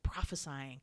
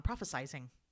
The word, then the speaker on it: prophesying: and there was always a little bit of the word like prophesying, or if you want to say it wrong, prophesizing.